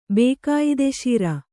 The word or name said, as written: ಬೇಕಾಯಿದೆಶೀರ